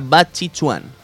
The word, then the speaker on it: Bajiquan: Me dijeron que Bajiquan, el arte marcial de Akira Yuki, se pronuncia Bachichuan.